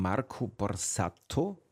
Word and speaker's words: Marco Borsato: So, jetzt sind wir ein bisschen abgeschweift von dieser wunderbaren Musik von Marco Borsato.